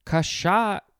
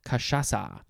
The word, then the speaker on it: cachaça: I think that's how it's pronounced. Cachaça. It is spelled C-A-C-H-A-C-A, but the last C has the little sort of question mark S-shaped thing at the bottom of it, which in this case is Brazilian Portuguese.